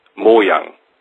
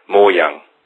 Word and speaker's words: Mojang: The right pronunciation of Mojang, like you say, is Mojang. So it's a short M-O and a Yang, like in Yin and Yang. Mojang, without an A, more an A-L.